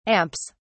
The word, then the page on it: amps: British English